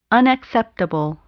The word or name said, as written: unacceptable